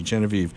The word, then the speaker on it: Genevieve: It's with the hard G. It's the hard G. Genevieve.